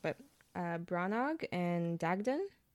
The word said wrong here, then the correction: Branna: Branna and Dagdon. You got Dagdon right, but Branna. Yeah. That's how they pronounce it in the audiobook anyways.